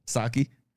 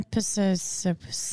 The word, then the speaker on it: Psaki: Saki. – What's her first name? – Jen. – Why did I think it was Jen Pisakis? – Because that's how you spell it, is like P-S-A-K-I.